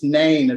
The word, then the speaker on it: Nain: And soon afterwards, Jesus enters a town called Nain, which is very similar to the Welsh spelling for those from North Wales of nine, speaking about grandmother. But it's Nain it's pronounced, as far as I understand.